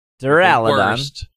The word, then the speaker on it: Duraludon: Duraludon.